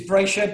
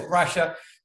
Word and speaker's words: Brasher: The pronunciation is Brasher, but don't worry about it. Everyone always says Brasher.